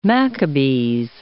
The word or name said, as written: Maccabees